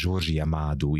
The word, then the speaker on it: Jorge Amado: hogy Georgia Mádú így kell kiejteni, de hogyha valaki meg akarja googlizni, akkor akkor Jorge Amado, vagy a spanyolosoknak Jorge Amado néven lehet megkant, de Jorge Amado, now you can tell again how can we pronounce well his name.